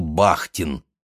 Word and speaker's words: Бахтин: Она рассказывала, что изучает Достоевского, и очень ей нравится русский филолог 20-х годов Михаил Бахтин, именно так с ударением на «а».